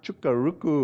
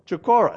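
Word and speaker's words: Chocorua: Last week, my daughter and I went climbing up in southern New Hampshire, up here, and I can't even pronounce the mountain, Mount Chukaruku or something. What is it? You're an expert up there. It's near Conway. Chukora? Chukora? Chukora. There, Mount Chukora.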